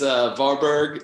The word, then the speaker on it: Varberg: Yep, it's Varberg and Engelholm.